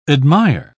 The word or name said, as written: admire